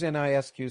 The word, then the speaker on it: NISQ: What does NISQ stand for? – Right, I pronounce it NISQ, as though the Q were a K.